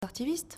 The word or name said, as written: artiviste